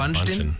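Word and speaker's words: Bündchen: But there's a D in there. – I know, but it's just said Bundchen. – Bundchen? Bundchen? – No, it's Bundchen.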